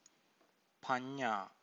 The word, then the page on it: panna: Pali